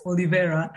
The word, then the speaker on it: Olivera: Okay, yeah, I will explain you just one thing, pronunciation of my name. It's Olivera. I really appreciate it. You're almost there. Like that's almost like you said.